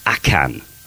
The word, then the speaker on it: ACAN: So in the world of magic, one of the holy grails to card magic tricks is called Akan. A A-C-A-N. ACAN is spelled ACAN.